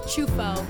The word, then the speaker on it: Ciuffo: Ciuffo.